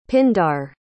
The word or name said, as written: pindar